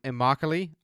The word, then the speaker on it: Immokalee: Um, I have head coach Scott Ricard from Immokalee. I finally learned how to pronounce it, guesting on the show today.